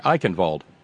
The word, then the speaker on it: Eichenwald: Kurt Eichenwald, we pronounce the W now, yes.